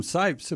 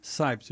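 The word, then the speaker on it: Scipes: I mean, yeah, if we just go to Kim Sipes, and by the way, it's spelled with a C, S-C-I-P-E-S, Kim Sipes,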